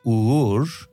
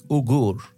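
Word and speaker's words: Uğur: I pronounce my name like Ur, but some people have difficulties to pronounce it. That's why I just tell my name like Ugur.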